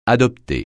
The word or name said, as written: adopter